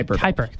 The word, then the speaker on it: Kuiper: It's called the Kuiper Belt, or K-U-I. – Kuiper. – Kuiper, yes.